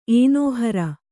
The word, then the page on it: ಏನೋಹರ: kannada